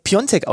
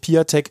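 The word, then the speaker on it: Piątek: Er wird Piontek ausgesprochen. – Was habe ich Nachrichten bekommen? Und ich bin mir ganz sicher, unser Kommentator Lukas Schönmüller und unser Experte Jonas Hummels ganz genauso. Ey, was seid ihr für Amateure? Ihr sprecht den komisch aus. Der heißt doch eindeutig Piatek. Nein, Er wird wirklich Piontek ausgesprochen.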